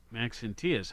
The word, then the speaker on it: Maxentius: Marcus Aurelius Valerius Maxentius. – Maxentius. I didn't even have it pronounced right.